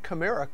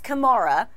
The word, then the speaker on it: Kamara: Well, he says his mom says Kamara, but he goes by Kamara is what he will tell his friends. So he says you can say Kamara or Kamara.